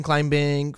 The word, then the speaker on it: climbing: I'm pretty sure it's a silent B. It's rock climbing, not rock climbing.